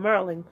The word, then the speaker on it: Maryland: Mary, like Mary, Mother of Jesus, and land, like farmland. Maryland.